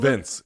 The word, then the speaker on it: Vince: Vink Pichel. Is that how I'm saying it correctly? – Vince. He doesn't spell it with an E, but it's Vince.